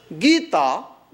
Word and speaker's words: Gita: So, Hindu temple is called Mandir, M-A-N-D-I-R. Okay? Mandir is six letters. Gita, the scriptural text of Hindus, G-E-E-T-A, it is Dirgha, so long E, you know. So, Hindu temple is called Mandir, M-A-N-D-I-R, six letters.